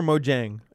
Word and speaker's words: Mojang: Is it Mojang or Mojang? – It's Mojang. – Okay, I've always had the soft J.